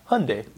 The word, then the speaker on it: Hyundai: We say it like it rhymes with Sunday. Yeah. – Okay. Yeah. It's Hyundai, and so I have to remind myself. – Their ads say that. They say Hyundai like Sunday.